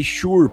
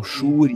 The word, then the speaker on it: Shure: compra um microfone Shure para mim, que se escreve S-H-U-R-E, né? Alguns falam Shure e outros Shure.